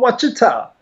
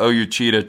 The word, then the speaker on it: Ouachita: And I said, O-U-A-C-H-I-T-A. It's, oh, Wachita.